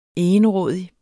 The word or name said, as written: egenrådig